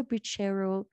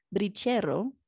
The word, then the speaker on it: brichero: Now, in this day and age, there's some discussion about what a brichero, I already said it in an English way, brichero, that's how they say it here, what that means.